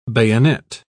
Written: bayonet

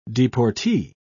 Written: deportee

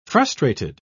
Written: frustrated

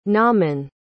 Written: naaman

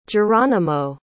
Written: jeronimo

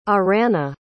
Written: Arana

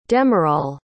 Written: demerol

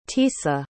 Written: Tisa

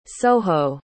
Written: soho

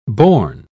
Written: bourne

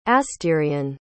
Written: astyrian

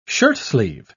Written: shirtsleeve

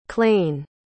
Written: clayne

